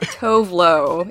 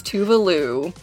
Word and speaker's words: Tove Lo: It's not Tovlo, it's Tuvalu.